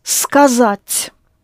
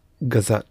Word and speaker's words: сказать: Now let us do the verb to say or to tell, the same, is сказать, sounds like scars, which you inflict on a person if you say something rude to him, yes? Scars, сказать, to say.